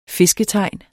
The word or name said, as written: fisketegn